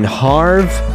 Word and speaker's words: Havre: And the lowest temperature that was on record over the weekend was negative 38 degrees in Havre, Montana. And that's spelled H-A-V-R-E. It's kind of like Brett Favre. That's the type of pronunciation there.